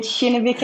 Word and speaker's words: Kinnevik: I can just help and say Kinnevik and Vækstfonden.